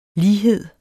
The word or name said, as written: lighed